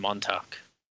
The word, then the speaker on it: Montauk: In Montauk. Is that how you pronounce it? – Yeah, you got it.